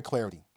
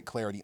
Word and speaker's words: Clardy: And give me the follow on Twitter as well, at Troy Clarity. At Troy Clarity. Last name is spelled C-L-A-R-D-Y.